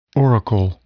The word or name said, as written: oracle